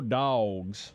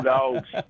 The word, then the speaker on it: Dawgs: They spell it D-A-W-G-S around there, so it's go dogs. – Go dogs.